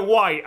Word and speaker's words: Wight: It's actually the Isle of Wight, and by Wight I mean like a white.